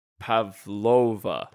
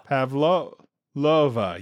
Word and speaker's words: Pavlova: Pavlova. – Pavlova, yeah.